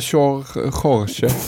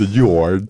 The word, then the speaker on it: Jorge: Men Jorge. Något sånt där då. Inte George.